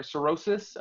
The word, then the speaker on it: cirrhosis: Cirrhosis of the liver.